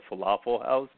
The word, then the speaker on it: Flaffle House: I call it the Flaffle House. Sal, are you there? – I am here, and you're pronouncing it correctly. It does sound exactly like the food, but it does not represent the food.